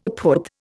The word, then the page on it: phut: Indian